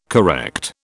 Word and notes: correct: British English

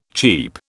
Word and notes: cheap: British English

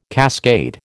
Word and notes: cascade: American English

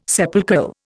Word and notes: sepulchral: Indian

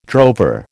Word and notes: drover: American English

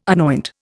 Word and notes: anoint: Indian

